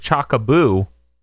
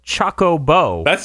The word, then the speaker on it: Chocobo: I will go with Chocobo, and I will go with Chocoboo. I will not go with Chocobo. That just seems wrong. – Guys, it's pronounced Chocobo. – That second Chocolina. – One's just wrong. There's no boo. It's bo.